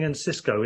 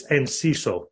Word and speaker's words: Enciso: And also, for everyone that's saying Encisco, it isn't Encisco either. It's Enciso. – It's not rhyme with disco. – He's South American. It's definitely Enciso.